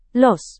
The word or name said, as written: los